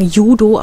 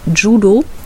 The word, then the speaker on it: Judo: Also der Text heißt Kusushi und ist ein Wurf beim, wie der normale, also wie wir alle sagen, Judo, aber es heißt eigentlich Judo. Korrekt ausgesprochen, musste ich mich auch belehren lassen.